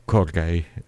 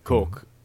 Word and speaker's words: Cork: Allora, il video tra l'altro è bellissimo perché è estratto live in un pub, penso, di una località irlandese, non so qual è la giusta pronuncia della località, Corgay. – Cork.